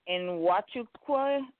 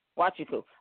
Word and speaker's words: Nwachukwu: I want to say Nwachukwu. – Nwachukwu, the N is silent. – Nwachukwu.